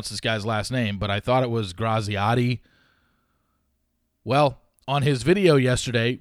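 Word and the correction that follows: Graziadei: his last name is pronounced Grazday. That's how he said, I'm Joey Grazday.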